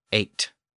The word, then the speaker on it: eight: And today, the GH is silent, simply giving us eight.